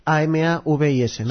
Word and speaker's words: Amavis: Yo también lo he pronunciado como Amavis, ¿no? Como Amavis. Amavis, ¿no? – Sí, eso es.